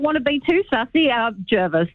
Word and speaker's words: Jervis: The locals say it's Jervis, and we've had quite a few people, even though the GPS will say Jervis and lots of Sydney side as well too. It's the Jervis family from back in the day. That's how they pronounce their name.